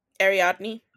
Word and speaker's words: Ariadne: Ariadne. – What? – I was going to say Ariadne. – Ariadne.